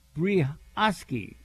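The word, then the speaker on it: Brioschi: And pronounced Brioski, says Stephen.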